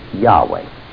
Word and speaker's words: Yahweh: Hebrew scholars are pretty generally in agreement as to what the vowel letters for that should have been, and that the correct pronunciation should be Yahweh. Now, there still is a certain amount of argument whether that third letter should be pronounced as a W or as a V.